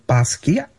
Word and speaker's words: Basquiat: We are going to be talking about his solo performance in honor of Jean-Michel Basquiat. Because there's no T, correct? – Yeah, you don't pronounce the T at the end. – Okay. – Just end on the A.